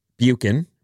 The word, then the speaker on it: Buccan: B-U-C-C-A-N. Buchan, right? – Yeah, okay.